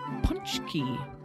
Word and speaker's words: pączki: This week on the podcast, we made pączki, which is spelled nothing like it's pronounced.